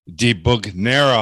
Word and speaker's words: DiBugnara: Today we have with us Ralph DiBugnara.